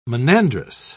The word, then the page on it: monandrous: British English